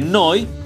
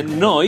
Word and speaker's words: Neu: Noi eh, que simplemente quiere decir nuevo en alemán, nuevo, se escribe Neu, se dice con un signo de exclamación, se dice Neu.